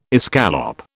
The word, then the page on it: escallop: British English